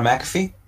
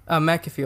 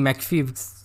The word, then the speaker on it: McAfee: What, John McAfee? – McAfee, okay. Yes, yeah. Because I'm saying McPhee.